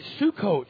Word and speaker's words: Sukkot: It's S-U-K-K-O-T. I'm like, the Bible can't say that. That's really bad, but it's Sukkot if you say it right. So if you think it says the other way, that's wrong. It's Sukkot.